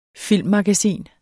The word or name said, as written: filmmagasin